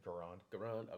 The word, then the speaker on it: Garand: Can we make an M1 Grand? Garand, did I say it wrong? Correct. – Garand. – Okay, thank you. To be used. – Or Garand. Sorry.